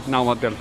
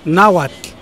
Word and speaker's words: Náhuatl: Se pronuncia la T y la L. – Sí. – Y en náhuatl es un sonido, no es una pronunciación, es tl. Y en español es tl.